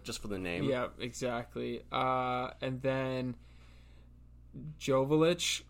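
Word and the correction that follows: Joveljić: scored Joveljic. My yeah. bad, just butchered that.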